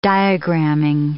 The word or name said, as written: diagraming